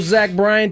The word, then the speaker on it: Zach Bryan: All right, trivia question for those Zach Bryan tickets.